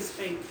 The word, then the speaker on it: Spink: It's Metro and Spink. – Yeah. S-P-I-N-K, all right? Like pink with an S on the front of it, all right? – By the pool place, isn't it?